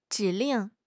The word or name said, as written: 指令